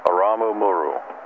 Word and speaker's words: Aramu Muru: Amaru? – It's spelled A-R-A-M-U, Aramu, then M-U-R-U, Muru, Aramu Muru.